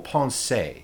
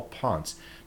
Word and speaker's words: Ponce: Now, if you were Spanish, you would say Paul Ponce, but as Norte Americanos, we would say Paul Ponce.